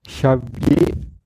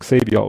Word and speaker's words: Javier: Dann schreibt André, äh, ihr habt Javier nicht richtig ausgesprochen. Javier mit CH wie wach. Also ich dachte immer, wird Xavier ausgesprochen, aber Ach. er behauptet, er wird Javier ausgesprochen.